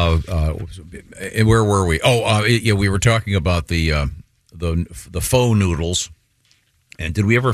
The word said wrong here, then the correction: pho: You mean the pho noodles? – Oh, pho. It's spelled pho, but it's pronounced pho.